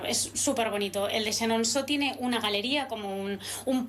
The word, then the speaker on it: Chenonceau: Chenonceau, muy bien, muy buena la pronunciación, ¿eh? – Bueno, genial.